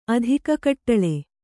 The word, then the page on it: ಅಧಿಕಕಟ್ಟಳೆ: kannada